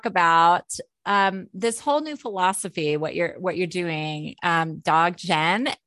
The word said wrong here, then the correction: Dzogchen: And can you tell me how to pronounce it? – Dzogchen. – Dzogchen. Okay. Beautiful.